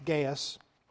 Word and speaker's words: Gaius: That's how it's pronounced, Gaius.